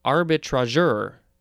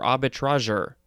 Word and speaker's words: arbitrageur: So this one is pronounced, I guess, arbitrageur or arbitrageur. A-R-B-I-T-R-A-G-E-U-R. It's a noun from 1870, one that practices arbitrage. Next is arbitral, A-R-B-I-T-R-A-L.